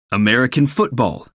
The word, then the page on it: american football: American English